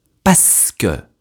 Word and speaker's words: parce que: À l'oral, « parce que » se prononce en fait « parce que ».